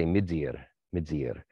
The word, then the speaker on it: Midir: So my Irish teacher, from Donegal, he would often, because of the northern dialect and the it's harder, he would say midzir.